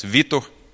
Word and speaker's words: Vitor: Victor, but in Portuguese is pronounced Vitor. He is a guy that I'm excited about because I got to know him a lot better this year.